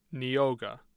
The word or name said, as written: neoga